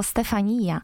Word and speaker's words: Stefania: Wszyscy byliśmy świadkami niewiarygodnego zwycięstwa ukraińskiej piosenki Kałuż Orkiestry Stefania. Tak naprawdę wymawiamy to słowo Stefania. W ten sposób też jest śpiewane. Taka jest linia melodyczna tej piosenki.